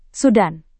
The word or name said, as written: sudan